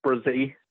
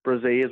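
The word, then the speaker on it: Bresee: And just a note on the pronunciation of his name, I'll just check it up here real quick. It's Connell actually had got it right. It's Bressy. Bressy is what we're going with, apparently, and that's according to the NFL.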